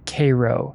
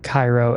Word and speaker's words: Cairo: Oddly enough, though, they pronounce Cairo as Cairo, C-A-Y-R-O, and not Cairo as in the Egyptian city, which is annoying to me for some reason, because it's still spelled like the Egyptian Cairo.